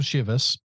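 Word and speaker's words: Chivas: 3:50, another glass of Shivas. I don't know why I said it that way that time. – That's how it's said. – Is it? – Yeah. – I wasn't sure. It sounded like Shiva, like we're morning.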